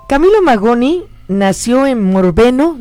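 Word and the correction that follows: Morbegno: Morbeño. – Morbeño. Morbeño, Italia. O sea, la N se pronuncia como Ñ la en italiano.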